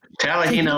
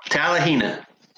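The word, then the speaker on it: Talihina: Tallahena, Oklahoma. Tallahena.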